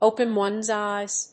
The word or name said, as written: open one's eyes